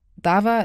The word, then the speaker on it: DAVA: Und wenn Sie sich wundern, warum ich das V in DAWA weich ausspreche, Dava ist auch ein türkisches Wort beziehungsweise ein arabisches und hat viele Bedeutungen, zum Beispiel Prozess.